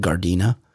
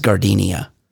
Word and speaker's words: Gardena: I often purposefully mispronounce things, and the song, while great, is especially great because of the mispronunciation of the city, the great South Bay city of Gardena as Gardenia.